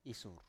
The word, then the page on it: isur: Biak